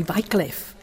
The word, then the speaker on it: Wycliffe: John Wycliffe, or the British say Wycliffe.